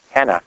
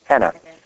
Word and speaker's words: henna: Is that spelled like the name Hannah? – Hannah, H-E-A. Yeah, Hannah. H-E-N-N-A. – Yeah, not like Anna. The name. – Hannah. – Hannah, the herb. – Yeah, the herb, Hannah.